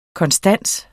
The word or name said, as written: konstans